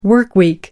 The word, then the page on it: workweek: British English